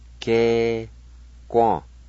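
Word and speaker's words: skequa: S-ke-guan.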